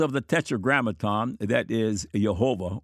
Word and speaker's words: Jehovah: but represent the original and true pronunciation of the profoundly significant divine name. The commonly repeated modern idea that the pronunciation Jehovah is a late and incorrect invention, while Yahweh is the true pronunciation of the name, is false.